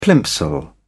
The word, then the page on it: plimsoll: British English